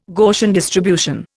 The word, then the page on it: Gaussian distribution: Indian